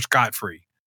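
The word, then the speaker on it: scot-free: That way, we are scotch free. Is that how you say it? Scotch free or scot free? – Scot free. – Scot free, not scotch.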